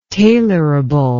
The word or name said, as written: tailorable